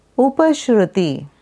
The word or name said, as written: Upashruti